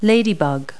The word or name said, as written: ladybug